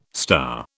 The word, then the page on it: star: British English